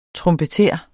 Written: trompeter

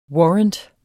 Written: warrant

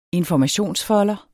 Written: informationsfolder

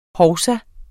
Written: hovsa

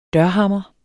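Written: dørhammere

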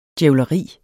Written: djævleri

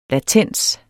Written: latens

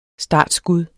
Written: STARTSKUDDET

